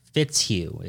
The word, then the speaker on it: Fitzhugh: Fitzhugh is like that shoe fits you. – It's pronounced that way.